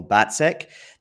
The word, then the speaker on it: Battsek: I'm joined by John Battsek.